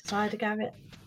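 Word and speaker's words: Siedah Garrett: Cedar Garrett. Cider, yes. Garrett? Cedar Garrett? – Cider Garrett, yeah.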